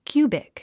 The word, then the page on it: cubic: kjúːbik; キュービク